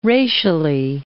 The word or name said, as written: racially